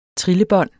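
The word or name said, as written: TRILLEBÅND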